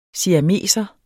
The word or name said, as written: siameser